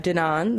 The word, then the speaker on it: Danone: Danone, the French multinational corporation.